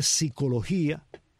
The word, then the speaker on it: psicología: Uno dice psicología y aunque no pronuncie la P, uno la siente.